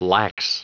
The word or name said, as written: lax